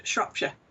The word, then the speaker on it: Shropshire: Telford, Shropshire. – Now, I want you to say Shropshire 10 times fast. Can you do that? – Shropshire, Shropshire, Shropshire, Shropshire, Shropshire, Shropshire, Shropshire, Shropshire, Shropshire.